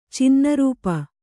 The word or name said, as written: ಚಿನ್ನರೂಪ